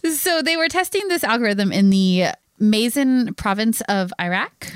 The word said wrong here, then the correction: Maysan: Oh, Paul's listening to this going, that is not how you pronounce that. – No, it's not how you pronounce it. I'm so sorry, Paul. – Probably Maysan. – Maysan? Okay. Yeah. M-A-Y-S-A-N.